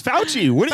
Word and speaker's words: Fauci: Oh, Fossey. – That's not how you say it. You are Italian. What are you doing? – Fossey. – I said Fossey. – It's Fauci.